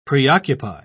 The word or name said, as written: preoccupy